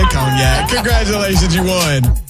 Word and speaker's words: Cognac: Sounds like she said Kanye. – It did sound like she said Kanye. I believe it's pronounced Cognac.